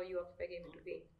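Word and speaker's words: UOQPI: And it's U-O-Q-P-I, but we pronounce it as You Occupy, as most people can't pronounce it.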